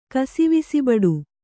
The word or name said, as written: ಕಾಸವಿಸಿಬಡು